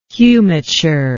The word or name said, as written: humiture